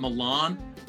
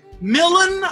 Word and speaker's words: Milan: And also, thank you for pronouncing it Milan. I don't know. – yeah. – Millen, I don't.